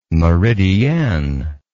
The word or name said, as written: meridienne